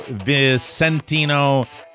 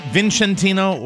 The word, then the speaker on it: Vicentino: And Camisano Vicentino, it's not Vincentino, or it's Vicentino. – Vicentino, I think, yeah. – What is it? – With a soft C. I think you had it right.